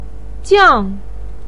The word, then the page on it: jiang: 4